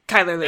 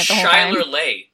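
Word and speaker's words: Chyler Leigh: Kyler Lee. – That's how you pronounce her name? – Yeah.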